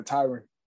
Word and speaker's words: Tyron: Okay, so I was watching All Access and I had seen Tyron. That's how you say his name, right? – Yeah, Tyron.